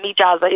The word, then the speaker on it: Mijaza: How do you pronounce it and where does it come from? – It's Mijaza, you could say.